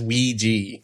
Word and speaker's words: Ouija: Google says Ouija. – Yeah. – Wow. – It's because that's how it's pronounced.